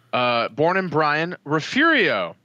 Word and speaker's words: Refugio: So, Refurio is actually refugio, but we added an R. It's refurio because reasons.